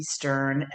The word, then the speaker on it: Stern: Stern, S-A-R-A. I don't have that silent H. The letter B, Stern, S-T-E-R-N.